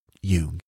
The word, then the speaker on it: Jung: And he said, that was a wonderful presentation, but it's Jung, not Jung.